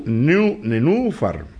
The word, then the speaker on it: nenúfar: Tienen un acento en la U. Nenúfar.